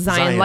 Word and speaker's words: Zion: Because the national park is pronounced Zion like lion.